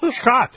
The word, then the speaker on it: Scott: Scott. – My name is spelled S-C-O-T-T. – That's what I said. – Oh, you said Scott. – S-C-O-T-T, Scott.